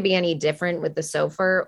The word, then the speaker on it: SOFR: And, you know, there's the conversion going on between LIBOR and how do you say that? SOFR. – SOFR. Mm-hmm. SOFR.